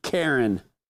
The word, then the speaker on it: Keren: The second is Kezia, and the third is Karen. But it's spelled K-E-R-E-N, which is the matriarch of all the girls you see on Facebook throwing fits, okay?